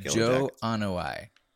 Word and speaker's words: Joe Anoa'i: Joe Anoa'i, I believe is how you say it.